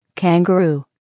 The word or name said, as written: kangaroo